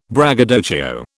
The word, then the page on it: braggadocio: British English